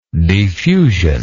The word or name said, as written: defusion